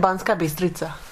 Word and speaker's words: Banská Bystrica: And we are in, how would you pronounce the name of this town? – We're in Banská Bystrica.